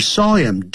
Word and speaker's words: him: punched me on the head 那么我们的鼻子是软的 He punched me in the nose N-O-S-E 所以特别要记住这些用法打呢都是要用 on 软的时候要用 in、啊、硬的部位要 in the nose N-O-S-E 所以特别要记住这些用法打呢都是要用 on 软的时候要用 in、啊、硬的部位要用 on好 The teacher saw him do it Saw him 或者 saw him Saw him 的时候呢那 H 就不发音